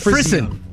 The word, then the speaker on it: frisson: Okay, so, you have Freon. Freezon. – I really just pronounced Frisson. Frisson.